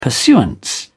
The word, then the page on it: pursuance: British English